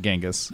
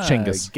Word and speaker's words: Chinggis: Because it's not Genghis. – Oh, it's Chinggis.